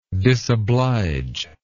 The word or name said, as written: disobligingness